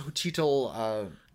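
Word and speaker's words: Xochitl: I think it's pronounced Jochitl...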